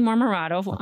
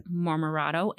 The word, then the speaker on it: Marmorato: Kristy Marmorato on Facebook. And they always spell my name wrong. So why don't you spell your name for them as well? – Kristy, K-R-I-S-T-Y, Marmorato, M-A-R-M-O-R.